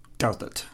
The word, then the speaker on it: Douthat: We've been frantically looking how to pronounce his name. So we think it's Ross Douthat.